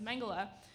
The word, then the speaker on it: Mengele: including Joseph Mengele. That is how you pronounce that name.